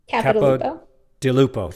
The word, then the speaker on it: Capodilupo: So Will Ahmed and John, and I'm going to try to pronounce Capodilupo.